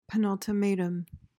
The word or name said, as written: penultimatum